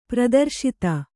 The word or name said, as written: ಪ್ರದರ್ಶಿತ